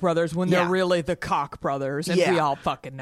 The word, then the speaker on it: Koch: Doing yeah by the way Coke brothers, we know it's actually pronounced cock. Stop it, guys.